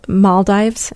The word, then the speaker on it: Maldives: Maldives.